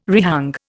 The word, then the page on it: rehang: Indian